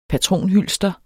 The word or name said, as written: patronhylster